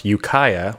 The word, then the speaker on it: Ukiah: We went to, as I'm now told is pronounced, Ukiah, which is about 2 hours north of San Francisco for a wedding reception.